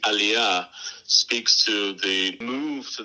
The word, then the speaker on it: Aliyah: Given your unique perspective, having grown up much of your life inside the United Kingdom, having made, is it Aliyah? Am I saying that correctly when you moved to Israel? – Aliyah, yes. Aliyah.